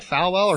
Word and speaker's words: Falwell: Winner of the Jerry Falwell Diversity and Tolerance Award. Is it Fowl? Am I saying it right? Falwell? – Falwell.